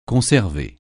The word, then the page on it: conserver: francais